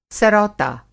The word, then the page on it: सरौता: سروتا